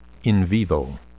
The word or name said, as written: in vivo